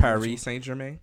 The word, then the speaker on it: Paris Saint-Germain: Paris Saint-Germain.